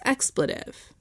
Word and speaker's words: expletive: It's expletive. Oh. It's not a long E, right, in the middle? – I don't know.